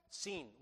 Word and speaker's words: Sin: Sin was the moon god. It's spelled S-I-N in English, but like sin, but it's Sin is this moon god.